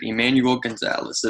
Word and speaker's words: Emanuel Gonzales: Today with us, I will be interviewing congressional candidate for California's 32nd district, Emanuel Gonzales.